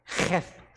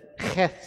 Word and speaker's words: Cheth: Let me say that right. Cheth stanza. Right? Hebrew says Cheth stanza.